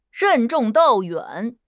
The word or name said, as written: 任重道远